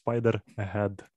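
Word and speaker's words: Spiderhead: А, неправильно. Spider-Head. – Head. Окей. – Okay. Ukrainian pronunciation, чи як воно там правильно? – Of course, if I talk in Ukrainian, but I have speak it in English, so Spider-Head.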